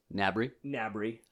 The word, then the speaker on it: Gnabry: I think it's Gnabry. G is silent.